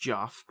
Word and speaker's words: Geoff: Geoff Johns, pronounced Joff, probably, developed The Flash, And he developed Young Justice, and he developed Stargirl, and he has writing credits on Arrow and Small World.